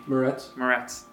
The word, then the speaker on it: Moretz: Moretz.